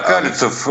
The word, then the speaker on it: Калицев: Возглавляет это хозяйство а, Алексей Калицев, если я ничего не напутал с э, ударением. – Да, Калицев, он достаточно известный человек, он давно уже в автомобильном бизнесе, так что в Москве он, работал.